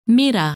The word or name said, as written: Mira